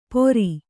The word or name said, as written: ಪೊರಿ